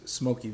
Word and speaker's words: Smokey: So artist name is Smokey Vintage.